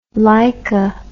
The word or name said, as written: leica